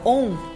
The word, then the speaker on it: Om: É, e essa sílaba, né, essa junção do O com, com o M, né? Mas que a gente escreve A-U-M, na verdade.